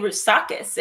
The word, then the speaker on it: Roussakis: Well, for those who do not know who Lilli Roussakis is, I said it right? – Yes.